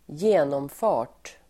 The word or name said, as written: genomfart